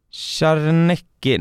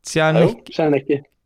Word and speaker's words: Czarnecki: har jag inte uttalet på, Czarnecki, nej, Czarnecki.